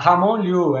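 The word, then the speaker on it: Ramon Llull: Cleide, falou certíssimo. Universidade Ramon Liu, é isso mesmo, a pronúncia é essa.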